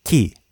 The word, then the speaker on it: ki: But the same character in Japanese is pronounced ki, with a hard K sound, just like English.